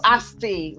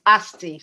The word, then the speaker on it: Asti: I'm Asti Rappaport, like the letters S and T. Just saying the letters S-T together, Rappaport. However you pronounce it, it's fine. I don't really care. – I've got it now. It's Asti.